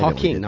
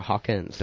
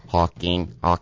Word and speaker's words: Hawking: It's Hawking, not Hawkins.